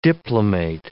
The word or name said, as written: diplomate